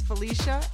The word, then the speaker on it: Felicia: My first name is Felicia, F-E-L-I-C-I-A, and my last name is Dudzinski, D-U-D-Z-I-N-S-K-I.